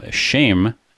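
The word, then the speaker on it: shame (שם): how shame in Hebrew, that's the pronunciation of the word name, not the English word, it's more than just kind of a label, like you were saying, like a moniker.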